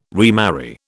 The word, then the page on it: remarry: British English